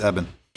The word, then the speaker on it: Eben: It's Eben.